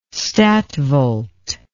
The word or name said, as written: statvolt